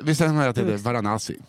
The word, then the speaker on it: Varanasi: Varanasi heter det, så Varanasi i Indien. Varanasi. – Varanasi kanske.